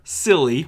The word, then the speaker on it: Cilley: For April Fool's Day in 1980, Boston TV news producer Homer Cilley. Homer. It actually rhymes with silly.